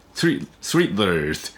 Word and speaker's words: slithered: す、す、す、 Slithered、そこで T H。 Slithered、 Slithered という発音です。